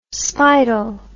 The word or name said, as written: speidel